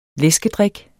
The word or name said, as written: læskedrik